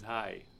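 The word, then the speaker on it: Hai: The word in German is hi.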